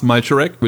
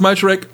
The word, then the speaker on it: Malcharek: My name is Lars Malcharek, which is L-A and then M-A. Mm-hmm. Put together, it's Lama, the German Lama, because we don't spell it with double L. It's just L-A-M-A. So Lars Malcharek, Lama, there we go.